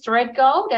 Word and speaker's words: Threadgold: Um, the surname is Threadgold, as in I'll thread gold through your content.